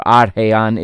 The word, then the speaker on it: Al Rayyan: in Al Rayyan, or Arhayan is how it's pronounced, Qatar.